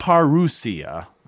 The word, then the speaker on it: parousia: Parousia, not parousia, but parousia, this concept.